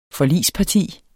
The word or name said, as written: forligsparti